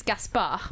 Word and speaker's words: Gaspard: The French pronunciation is Gaspard.